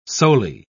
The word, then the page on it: soli: British English